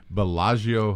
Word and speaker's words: Bellagio: Bellagio Hotel.